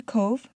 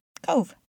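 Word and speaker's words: Cobh: That's how you pronounce it. C-O-V as in Victor, E as in Edward. However, that's not how you spell it. It is actually spelled C-O-B as in boy, H, Cove.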